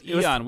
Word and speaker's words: Aeon: It is pronounced Aeon.